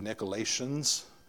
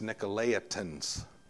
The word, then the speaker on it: Nicolaitans: Now, just for pronunciation, look at that word real carefully, because the I comes before the T. If it was after the T, it would be Nicolaitans, but before it, it's Nicolaitans. Now, the Nicolaitans, we don't know nothing about, except we know that they were drawing people from truth into false teaching.